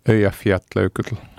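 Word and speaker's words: Eyjafjallajökull: Ako sa volá? – Ja Fiat Leukutl. – A výborne, takto presne.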